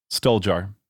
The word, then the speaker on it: Stoljar: Stoljar.